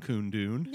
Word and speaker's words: Kundun: That's how it's pronounced, right? – Yeah, that's right. – Isn't it? I could cut this part. – So K-U-N-D-U-N. – Yeah. I think it's Coon Dune.